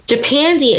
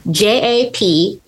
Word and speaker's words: Japandi: Japandi is a stylistic aesthetic that takes modern Japanese design with Scandinavian minimalism. – How do you spell it? – Japandi. Japandi. So there's Scandi and you have Japan, Japandi.